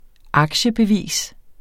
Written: aktiebevis

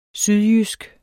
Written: sydjysk

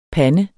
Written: pande

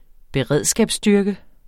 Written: beredskabsstyrke

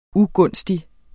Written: UGUNSTIGE

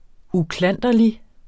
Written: uklanderlig

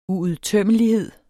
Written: uudtømmelighed

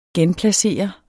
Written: genplacere

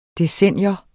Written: DECENNIET

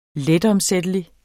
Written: letomsættelig

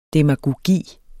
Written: DEMAGOGI

